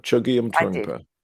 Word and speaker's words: Chögyam Trungpa: Chögyam Trungpa.